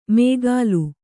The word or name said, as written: ಮೇಗಾಲು